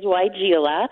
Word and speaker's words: Weigela: So people don't know how to pronounce that plant, but it is Weigela.